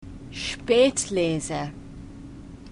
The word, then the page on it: Spätlese: German